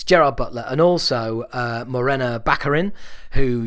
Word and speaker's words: Gerard Butler: And the star of this movie is Gerard Butler. Or, as it should be pronounced, Gerard Butler!